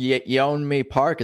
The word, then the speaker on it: Yeonmi Park: Yeonmi Park, is that how you say her name? – Yes.